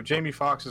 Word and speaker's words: Jamie Foxx: Sorry, am I saying this right? Jamie Foxx, is that who's in it? – Yeah, I believe yes. he's the one in that Jamie Foxx, yes. So Jamie Foxx is in that movie.